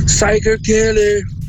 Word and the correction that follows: Psycho Killer: O nome da música é Psycho Killer. Psycho Killer. Se você quiser pronunciar direitinho, é